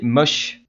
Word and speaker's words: MOSH: Donc, tu vas nous parler pour cette dernière de quel groupe Alors, bah, pour la dernière, je vais vous présenter MOSH. M-O-S-H, ça ah.